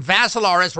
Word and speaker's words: Vassilaris: So sign up for Rumble and look for Dimitri with three I's, D-I-M-I-T-R-I. The last name Vassilaris rhymes with Assilaris, and you can actually see the show.